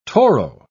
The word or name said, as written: toro